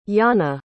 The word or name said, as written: yana